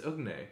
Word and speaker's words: Ugne: Ugne, have we figured out how to pronounce? I'm fucking. – I think it's Ugne. – It's Ugne? – Yeah. – Like neigh like a horse.